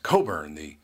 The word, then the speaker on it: Cockburn: And after the show, he came up to me and said, "Actually, my name is pronounced Coburn. The C and the K are silent." And I said to myself, as far as I'm concerned, the C and the K are the least silent letters in the alphabet.